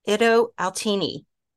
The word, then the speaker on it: Itto Outini: And I have a very special guest with me today, Itto Outini.